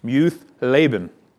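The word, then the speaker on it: Muth-labben: Notice the title, to the choir master according to, if you don't know that word, I don't either, but it's pronounced Muth Laban, and it's a psalm of David.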